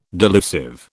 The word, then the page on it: delusive: British English